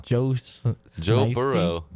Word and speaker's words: Joe Burrow: Joe Burrow. Joey Burrow. – No, it's Joe Burrow. – Burrow. – You got to pronounce it like Coach O. Oh, Joe Burrow.